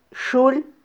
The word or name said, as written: xul